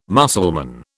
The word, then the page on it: Mussulman: British English